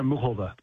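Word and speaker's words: Muchová: but I can't help but bring up that Karolina Muskova? Is that how we say her name? – I mean, she says Karolina Muchova.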